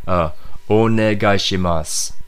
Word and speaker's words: onegaishimasu: But if you read it, it'll actually have a U at the end, so it'll look like it says onegaishimasu. – Oh, yes. – But the U is silent, so just keep that in mind when you're reading that word.